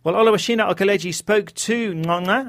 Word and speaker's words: Ngcongca: His name Ngcongca is very difficult to pronounce. It's spelt N-G-C-O. Ngcca, pronounced Ngcongca. His club, Troyes, are bottom of the French League One with no wins in their 19 games.